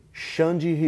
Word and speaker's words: Xande: Saulo and how do you pronounce it? Xande? – Xande Ribeiro. Xande. The name is Alexandre Conceiro Ribeiro, but Xande Ribeiro is a nickname.